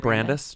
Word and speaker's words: Brandis: Isn't it Brandis? Just Brandis? – Yeah. Sorry. Mm-hmm. Not like the college.